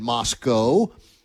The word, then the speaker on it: Moscow: Lamar will be at Idaho in the Kibbe Dome playing in Moscow. That's how they say it.